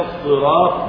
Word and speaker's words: الصراط: أنا أنطق كلمة الصراط بالصاد بالصاد، وهل هناك شيء غير الصاد؟